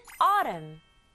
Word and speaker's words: autumn: 가을은 autumn. autumn이에요. 조금 어렵죠. A, U가 만나서 어 하는 소리 나요. 그리고 T에다가 소리 나는 U, 그리고 소리 나는 M. N은 소리가 안 나네요.